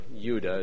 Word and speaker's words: Judah: The word Jew comes from the first syllable of Judah, or Yuda, as everybody else in the world says. We're pretty much the only ones that speak J, I guess.